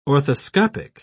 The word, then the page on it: orthoscopic: British English